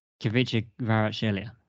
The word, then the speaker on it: Kvaratskhelia: the Kvaratskhelia. – Kvaratskhelia.